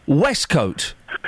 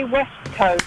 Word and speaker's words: waistcoat: It's spelt waistcoat. It's pronounced Westcoat.